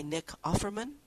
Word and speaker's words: Offerman: There's a wayward coach, and he's played by Nick Offerman.